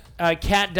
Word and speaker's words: Kait: K-A-I-T? Cat? – Yeah, yes, Cat Dias. – Okay, well, I don't play Gears of War, so don't fucking murder him. – Murder me. Cat Dias Xbox One controller featuring a similar icy look and texture along with Cat's emblem.